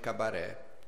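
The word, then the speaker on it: Cabaret: para achar no Facebook, Nostalgique Cabaré, com T mudo, né? Nostalgique se escreve com Q-E no final e Cabaré T, com T mudo, né?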